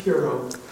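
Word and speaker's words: Tureaud: I'm not sure how his last name is pronounced, but you know him. At least those of you who remember the 80s know him as Mr. T. Are you a fan of Mr. T back in the day? What's that? – It's Tiro.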